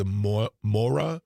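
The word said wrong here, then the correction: Māori: tribe? – Maori tribe. – The Maori. – Thank you for correcting me.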